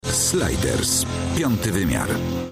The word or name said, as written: Sliders - Piąty wymiar